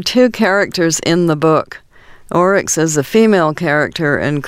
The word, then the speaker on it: Oryx and Crake: It sounds like Orcs and something, as in Tolkien, you know, so what's the exact title? – Let me spell it for you. Oryx, O-R-Y-X, and crake, C-R-A-K-E.